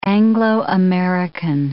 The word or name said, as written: Anglo-American